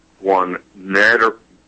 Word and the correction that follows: Guanarito: Guanarito virus.